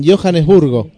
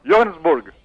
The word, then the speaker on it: Johannesburg: En Johannesburg. Así se pronuncia, señor. – ¿En Johannesburgo? – No, Johannesburg. Pronuncie correctamente, por favor.